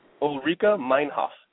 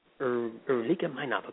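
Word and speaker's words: Ulrike Meinhof: Ulrika Meinhof. – Ulrika Meinhof.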